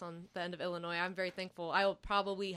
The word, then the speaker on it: Illinois: I spent the whole time I was getting ready to come to college, I explained to people, it's Illinois. – Yeah. – And the first placement test I had here, the proctor giving the placement test pronounced the S on the end, and I just wanted to die.